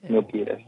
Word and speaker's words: Milpitas: This is Milpitas. I say that right? – Yes, it's Milpitas.